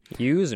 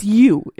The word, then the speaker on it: ewe: I've never heard you, Well, it's you is how you say it.